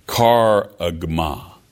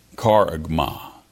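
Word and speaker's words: karagma (χάραγμα): This this Greek word is it's pronounced as best as I can pronounce it, karagma, karagma. As you read it here in English, the Greek as it's put in the English letters, it's like chargama.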